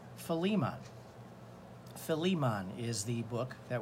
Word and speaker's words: Philemon: The best way I can think of to remember is take the word Philly and just take the emphasis on the Li part, Philemon.